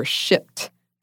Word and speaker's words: Shipt: I work as an independent contractor for Shipt. Make sure you pronounce the P.